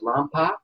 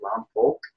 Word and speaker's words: Lompoc: Lompoc, a lot of people pronounce it Lompoc, but that's not the way you say it. You say it Lompoc.